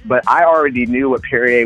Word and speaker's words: Perrier: I think the proper pronunciation is Perrier.